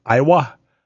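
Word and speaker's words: Aiwa: Iowa commercial. – Is it pronounced like the state? – That's what I'm wondering. It's A-I-W-A. Iowa.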